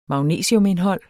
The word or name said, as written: magnesiumindhold